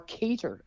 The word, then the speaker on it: Qatar: You've got Canada versus Qatar, or cater, as apparently it's supposed to be called.